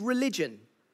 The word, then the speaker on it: religio: It's called religion. In the Latin, it's pronounced religio, and it means to be obligated or to be bound to something.